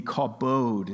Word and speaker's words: kavod: K-A-V-O-D, kabod is how it's pronounced, which more literally, like, it means wealth, which would make sense in the context of this passage.